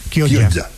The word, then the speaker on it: Chioggia: Gioia, no sé pronunciarlo. – Gioia. Chioggia. Chioggia, como dicen ellos, Chioggia.